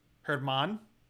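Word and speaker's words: Germán: Uh, um, Jermaine Marquez. I mean, Herman, Come on, Jermaine.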